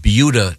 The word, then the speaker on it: Buda: we have yet to get to sports, but we were discussing a very interesting news item from Beulah, Texas. – Mm-hmm. – Christy, insist on saying. – No, that's how it's pronounced.